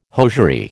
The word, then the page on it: hosiery: American English